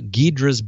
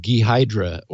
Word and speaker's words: Ghidra: After our mention of it a couple months ago, I received all sorts of terrific feedback about Ghidra's backstory and proper pronunciation because I was clueless. I think I was saying Ghidra or something. Anyway, several people said, Steve, it's Ghidra.